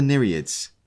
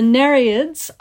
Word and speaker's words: Nereids: what are the Nereids? – So the Nereids are the daughters of Nereus.